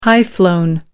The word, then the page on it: high-flown: American English